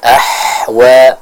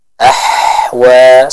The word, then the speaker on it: أَحْوَى: Tertukar membaca huruf ha dengan huruf ha. Misalnya ja'alahu ghusaan ahwa. Nah, ini kurang tepat ya. Yang tepat kita membacanya dengan huruf ha yang lembut, yang mana ha ini dibaca dari tenggorokan atau dikeluarkan dari tenggorokan bagian tengah.